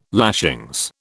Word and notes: lashings: British English

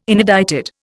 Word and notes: inedited: Indian

